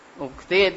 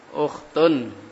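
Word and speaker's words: Ukhtun (أخت): Ukhti itu ada ya ya. Kalau tidak ada ya-nya jangan dibaca ukhti. Kalau belakangnya tidak ada ya, jangan dibaca ukhti. Bacanya? Kalimat yang tunggal biasanya dibaca duma, jangan dibaca kasroh. Kalimat tunggal sendirian seperti itu, bacanya adalah pakai duma. Semuanya pakai duma di situ. Ukhtun duma tanwin.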